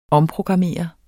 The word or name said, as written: omprogrammere